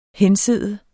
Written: hensidde

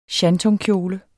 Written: shantungkjole

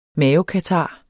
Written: mavekatar

